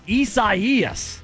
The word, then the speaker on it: Isaias: Come on, Isaias. That's how you say it.